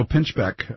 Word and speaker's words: Pinchbeck: It's edited by Daniel Pinchak and also Ken Jordan. Daniel Pinchbeck, let me say his name correctly.